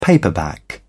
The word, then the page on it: paperback: British English